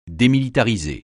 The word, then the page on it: démilitariser: français